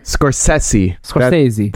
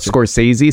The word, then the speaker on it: Scorsese: Scorsese. That's how you pronounce it. Not Scorsese, Scorsese.